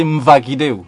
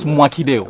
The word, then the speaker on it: Mwakideu: Because the Germans, they say Mwakideu. And that's obviously not the right way to say it. It's Mwakideu.